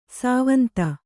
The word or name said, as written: ಸ್ವಾಂತ